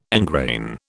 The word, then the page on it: engrain: British English